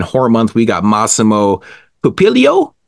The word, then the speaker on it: Pupillo: Like, it's ridiculous, but Massimo Pupillo. – Pupillo. – Pupillo, yep. – So yeah, I think that's right.